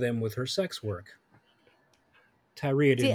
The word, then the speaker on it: Tyria: Tyria Moore. Yes. – Okay. – It's T-Y-R-I-A.